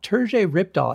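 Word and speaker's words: Terje Rypdal: Terje Ripdahl, I believe, is the pronunciation or the one I'm going with for the moment.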